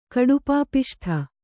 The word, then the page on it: ಕಡುಪಾಪಿಷ್ಠ: Kannada